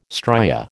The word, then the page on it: stria: American English